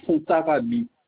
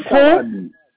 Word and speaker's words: Fontarabie: Voilà, eh bien, ça se trouve 673 chemin de Fontarabie. – 673 chemin de Fond. – Fontarabie. Pont, comme ça se prononce, Fontarabie, avec un E.